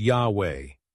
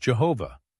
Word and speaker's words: Yahweh: No one today knows for certain how to pronounce this name, but the most common pronunciation now is Yahweh. A common, though errant, earlier rendering was Jehovah.